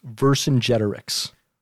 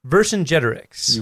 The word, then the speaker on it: Vercingetorix: So just so you know, the word is pronounced Vercingetorix. – Vercingetorix. – You got it.